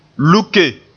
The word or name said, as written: luke